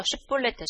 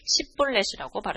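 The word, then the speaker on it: 쉬뿔렛 (שִׁבֹּלֶת): 에브라임 사람들이 그렇게 바로 말하지 못하고 시뿔렛이라고 발음하면 에브라임 사람들은 쉬를 발음할 수 없었습니다. 그래서 쉬뿔렛 대신에 시뿔렛이라고 발음하였습니다.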